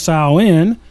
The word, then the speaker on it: Samhain: So the ancient origins, the Celtic festival of Samhain, actually pronounced Samhain, was 2,000 years ago, mostly in the area that now is Ireland, the United Kingdom, and northern France.